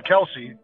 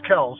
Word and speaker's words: Kelce: everybody was calling him Kelce. Apparently the correct pronunciation, the standard pronunciation is Kelce.